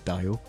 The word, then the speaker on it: Dio: Did you just pronounce Dio Dio? – Whatever. – His name is Dio.